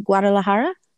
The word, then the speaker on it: Guadalajara: Because your mom is from Guadalajara, right? Did I say that right? – You said it correctly, but she is not from there.